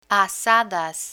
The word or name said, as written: azadas